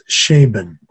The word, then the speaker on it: Scheeben: It's M-A-T-T-H-I-A-S, Matthias. And Schaeben is pronounced, it's spelled S-C-H-E-E-B-E-N, Matthias Schaeben.